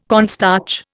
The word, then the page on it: cornstarch: Indian